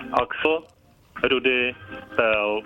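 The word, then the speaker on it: Axel Rudi Pell: Axel, Rudy, Pel.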